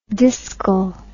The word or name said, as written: discal